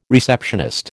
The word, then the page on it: receptionist: American English